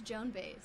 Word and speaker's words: Baez: I'm pretty sure it's just Joan Baez. – It's Joan Baez. – Baez. You keep adding extra. – Not syllable.